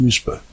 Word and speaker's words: WSPR: Our February Forum Night will be all about WSPR, pronounced Whisper, which stands for Weak Signal Propagation Reporter.